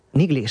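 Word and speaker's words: Neagley: Bueno, pues si os parece, vamos con la siguiente, que es Nigli. ¿Lo he dicho bien? – Nigli, sí.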